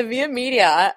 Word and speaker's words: Via Media: The Via Media. Am I saying it right? That's great.